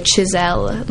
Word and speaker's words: Chissell: And then on YouTube, under Skylar Chiselle.